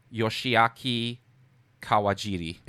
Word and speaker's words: Yoshiaki Kawajiri: This includes Masao Maruyama, Osamu Dezaki, Rintaro, which is a pseudonym for Shigeyuki Hayashi, and also Yoshiaki Kawajiri.